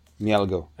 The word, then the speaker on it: Mielgo: I can't remember if it's E-G-L-O or E-L-G-O. – I-E-L-G-O. – Say again? – I-E-L-G-O, yeah. Mielgo.